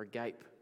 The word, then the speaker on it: agape: Agape is the word. Or agape, or agape, if you want to pronounce it that way.